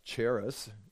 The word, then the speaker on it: Jairus: Jair, as in chair with us, Jairus.